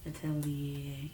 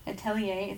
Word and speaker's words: Atelier: How would you say that? – Atelier, yeah. – Atelier. It's A-T-E-L-I-E-R. Atelier, whatever, cologne.